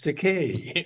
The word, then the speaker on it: Takei: And I did shows, a guest starring role as Gay George Takei. It rhymes with gay.